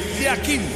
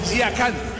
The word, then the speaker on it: Yakin: Uno es pronunciación suiza y la otra pronunciación turca. En el suizo se pronuncia así: Murat Yakin. En turco, Yakan. Cambia la última parte de su apellido.